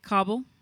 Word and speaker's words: Kabul: Kabul? – Kabul. We can pronounce it Kabul, can't yeah. – you? You say Kabul, I say Kabul.